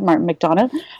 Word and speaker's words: McDonagh: Martin McDonagh.